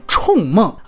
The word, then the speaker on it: 铳梦: 准确应该念“ 冲梦” 啊，四声。